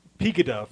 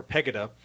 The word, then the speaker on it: Pegida: Pegida, for short, if I'm pronouncing that right, or Pegida.